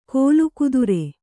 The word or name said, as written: ಕೀಲುಕುದುರೆ